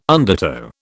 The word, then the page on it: undertow: British English